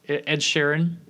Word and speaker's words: Sheeran: Ed Schran. – Ed Schran. It turned into Schran. Last time it was Sheeran. – Sheeran. – Ed Sheeran. – Sheeran. – Not Sheeran. No idea. Ed Sheeran.